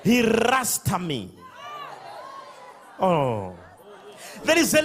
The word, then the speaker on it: Ratsah: But it's not pronounced like a Rasta. It's pronounced like Ratsah.